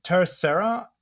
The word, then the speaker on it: Tercera: Michelle is a partner at Terracera. Did I pronounce that correctly? Michelle, you can correct me. – You did, yep. Spanish for third.